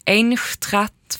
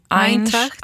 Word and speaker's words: Eintracht: Och nya klubbadressen blir, nu ska jag försöka uttala det här trots att det inte kan tyska, Eintracht Frankfurt. – Eintracht. Jag tror det är Eintracht.